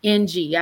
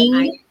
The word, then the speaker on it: Ng: One's name was Leonard Lake and the other one was Charles Ng, N-G. I don't know if I'm pronouncing Ng. – That. – Yes, like Ing. – Okay, Ing.